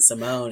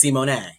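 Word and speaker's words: Symoné: So, you know, her name is Raven Simone, and well, it's Simone. It's Simone.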